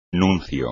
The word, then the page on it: nuncio: Spanish